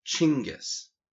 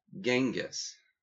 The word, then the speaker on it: Chinggis: The proper pronunciation is actually Chinggis, not Genghis.